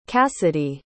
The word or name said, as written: kassidy